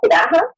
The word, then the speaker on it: Hephzibah: Is that how it's pronounced? Is it Hephzibah or Hephzibah? – Hephzibah. – Hephzibah, okay. You write about Hephzibah as a woman who played a leading role in bringing on the end of days.